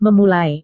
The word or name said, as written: memulai